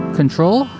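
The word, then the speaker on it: CKTRL: Underneath me, new music from Control, I believe. It's pronounced, it's C-K-T-R-L.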